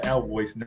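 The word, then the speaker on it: Alvoyce: Spell that Alvoice name too. How do you spell that? – A-L-V, like Victor, O-Y-C-E, Alvoice.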